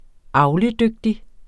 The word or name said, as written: avledygtig